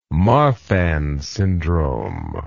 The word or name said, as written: marfan syndrome